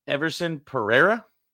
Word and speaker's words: Everson Pereira: Let's talk about Everson Pereira. I said that right? – There you go. Yes, sir.